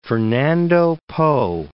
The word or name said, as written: Fernando Poo